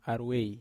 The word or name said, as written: arwei